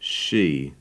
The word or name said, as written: she